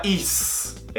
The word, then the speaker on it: is: Não é is. É is. Sonzinho de Z.